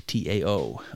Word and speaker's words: Tao: It's called the Tao Te Ching. Uh, it is spelled with a T, T A O. Um, so, you know, it's not it's it's not Tao, it's Tao with a D sound.